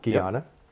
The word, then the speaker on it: Guyana: Am I saying that right? – Guyana. – Yep.